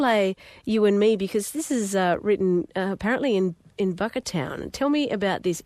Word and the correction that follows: Bookertown: I said that correctly, didn't I? – Almost. Booker. – Oh, Booker. Sorry, like bookend. Yeah. Booker. Bookertown.